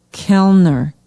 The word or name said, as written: kellner